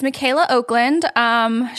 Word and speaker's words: Michaela Okland: It's Michaela Okland.